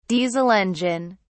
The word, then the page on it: diesel engine: British English